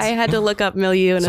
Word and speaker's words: milieu: Am I saying it right? Milieu? – Yes. – All right. Okay. – It's phonetic.